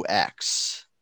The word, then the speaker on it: Mailloux: Mailloux? Yeah. Mailloux. It's Mailloux. That's French, right? – Yep.